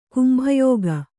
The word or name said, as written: ಕುಂಭಯೋಗ